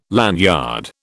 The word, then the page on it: lanyard: British English